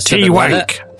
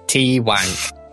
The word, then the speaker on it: Twank: It's pronounced Twank. Don't be going in there with any of those nasty twanky jokes. He will up the price. He's a stickler like that. But yeah, it's Zemnian.